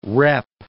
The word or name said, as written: rep